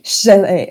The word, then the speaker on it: Chelles: You don't pronounce the S at the end. – Oh, okay. Chelles.